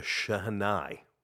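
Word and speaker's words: shehnai: For those folks that do not know what the Chennai is, and that's the pronunciation. That's the proper pronunciation.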